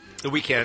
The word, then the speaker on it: The Weeknd: The Weeknd is in the news today. – The Weeknd.